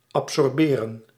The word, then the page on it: absorber: France; [ap.sɔʁ.be]